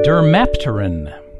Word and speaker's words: dermapteran: The word for round two is dermapteran. Dermapteran. That is spelled D-E-R-M-A-P-T-E-R-A-N.